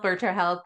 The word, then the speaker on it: Virta Health: So we got Vita Health, Virta Health. I just wanted to correct the pronunciation on that.